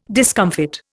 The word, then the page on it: discomfit: Indian